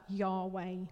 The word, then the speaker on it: Yahweh: So one of the names of God that's used in the Old Testament is written down using the Hebrew consonants YHWH. So over time, in English, when we've translated it, we pronounce that Yahweh.